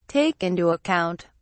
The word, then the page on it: take into account: British English